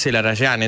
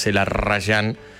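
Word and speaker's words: Zelarayán: Se alinearon los planetas y en un mismo mercado de pases, en un mismo año, llegó el Mudo Vázquez, llegó Celarayán, que lo digo así porque se pronuncia así, lo digo incluso a algunos colegas, es Celarayán, es un apellido armenio, no es Celarayán.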